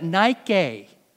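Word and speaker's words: nike (νίκη): That one right there, you pronounce that nike.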